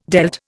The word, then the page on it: dealt: Indian